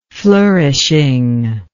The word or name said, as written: flourishing